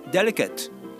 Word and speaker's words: delicate: デリケートあの最初にアクセントです